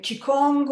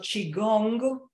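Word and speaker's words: צ'יגונג (气功): אמ, צ'יקונג, או בהגייה היותר מדויקת שלו צ'יגונג, מה לעשות סינית זה נורא נורא תלוי איך אומרים את הדברים, אה, זה למעשה תרגול אנרגטי גופני מאוד מאוד עתיק.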